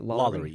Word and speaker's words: LaLaurie: He bought the LaLaurie mansion, but then Is that how you say it? – LaLaurie, yeah. And that's on good authority.